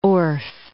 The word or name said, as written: Orff